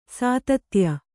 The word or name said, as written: ಸ್ತುತ್ಯೆ